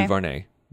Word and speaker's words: DuVernay: DuVernay, the director of Selma and the 13th and Wrinkle in Time.